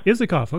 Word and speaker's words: Isakoff: Is it Michael Isakoff? – Isakoff.